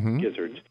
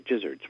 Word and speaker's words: gizzards: That's Is it gizzards or gizzards? Because it's spelled with a G, gizzards. But a lot of people think it's pronounced like a J, gizzards.